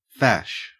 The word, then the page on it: fash: Australian English